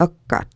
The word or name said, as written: kkat